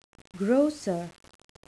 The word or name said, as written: grocer